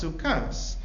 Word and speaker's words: Sukkos: It's read Sukkos, but it's pronounced Sukkas.